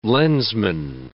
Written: lensman

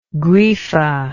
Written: grefa

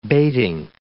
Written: bating

